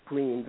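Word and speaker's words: Greenz: and it's spelled with a Z, you know, and it's Greens, and it's a salad restaurant.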